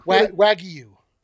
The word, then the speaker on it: Wagyu: Wagyu, yeah. Wagyu, because there's a Y. – I love that you asked me and then you pronounced it the way you wanted to. – Is that not? Did I say it wrong? – Wagyu. – It's Wagyu.